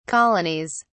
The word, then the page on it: colonies: British English